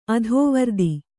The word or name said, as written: ಅತಿವೃತ್ತ